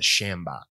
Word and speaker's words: sjambok: Yeah, my favorite gimmick, though, is a thing called the Shambok, which is spelled S-J-A-M-B-O-K.